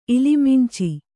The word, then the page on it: ಇಲಿಮಿಂಚಿ: ilimiñci